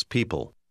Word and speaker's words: people: Pronunci la combinazione E-O, come se fosse un suono I largo. – She likes people.